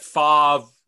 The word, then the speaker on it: Favre: He's like, Brett Favre.